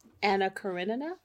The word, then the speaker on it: Anna Karenina: And we are excited to continue with our reading of, and let me try to say it correctly Anna Karenina I think you're right.